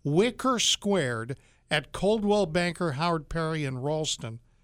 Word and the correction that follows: Walston: And that's not right. It's Walston.